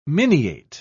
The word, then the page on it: miniate: British English